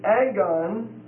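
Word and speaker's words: agon (ἀγών): It's the compound of the preposition epi, E-P-I, which means upon, and then the word agon, A-G-O-N, a long O, agon, A-G-O-N, which means a contest, a battle.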